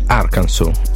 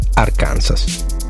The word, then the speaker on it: Arkansas: Quando molti hanno saputo che correttamente si pronunciava così Arkansas e non, come facevamo noi lettori di Tex Wheeler, Arkansas.